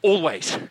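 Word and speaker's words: Alvvays: And, you know, we went to watch a fantastic Canadian indie band called Always last night, spelled All Vays.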